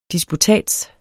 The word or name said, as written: disputats